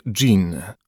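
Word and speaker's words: Jin (金): Pochodzi z języka chińskiego i wymawia się je Jin, oznacza złoto, metal.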